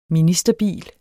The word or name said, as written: ministerbil